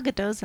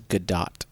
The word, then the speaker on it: Gadot: Can we start saying her name properly? Better. Her name is Gadot. She pronounces her own name Gadot on Saturday Night Live. – Thanks. Yeah, because she's like Israeli, not French. – Yeah, it's like a hard T.